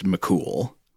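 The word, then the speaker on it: Mac Cumhaill: But the other one is spelled more like Fionn, and then the last name is Mac, C-U-M-H-A-I-L or H-A-I-L-L, which from what I understand, I know pronunciation of a lot of these Irish words is notoriously tricky for English speakers like us, but I think that is also pronounced pretty much just McCool.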